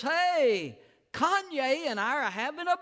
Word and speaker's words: Kanye: And I was told it was pronounced Kanye. Kanye.